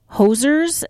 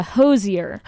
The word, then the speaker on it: Hozier: I always say it like there used to be these two guys and they're Canadian. And they would call everyone hosers. And so I always want to say hoser instead of, can you say it correctly for me? – It's a Hozier.